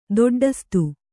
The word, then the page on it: ದೊಡ್ಡಸ್ತು: doḍḍastu